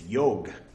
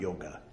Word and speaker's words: yoga: Or Naga Kanya, for those who pronounce the A's at the end of Sanskrit words, which you're not supposed to do. It's yog, not yoga.